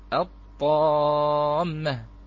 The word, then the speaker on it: الطامة: يعني عندما نقول مثلا الطامة، الطير، مثلا الرحمن، التنور، مثلا على سبيل المثال، فهذه اللام موصولة، وموصولة بالحرف أخوتي الكرام، بما أنها لا تلفظ، هذه اللام لا تلفظ الآن. عندما نقول مثلا الطامة، أين هذه اللام؟ عليكم السلام ورحمة الله. أين هذه اللام بارك الله فيكم؟ كلمة في كلمة الطامة أدغمت.